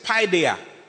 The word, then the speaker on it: paideia: The Greek word is paideia. Paideia is spelled P-A-I-D-E-I-A. P-A-I-D-E-I-A. P-A-I-D-E-I-A. P-A-I-D-E-I-A.